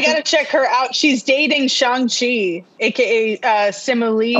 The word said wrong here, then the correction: Simu Liu: I think I might be saying his name wrong. Simu Liu.